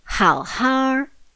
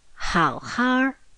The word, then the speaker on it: 好好: 请好好照顾自己。在这里第二个好是没有声调。没有声调。请好好照顾自己，请好好照顾自己。